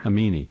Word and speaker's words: Amini: Amini? – Yes, Amini. – Amini. A-M-I-N-I.